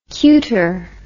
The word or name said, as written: cutor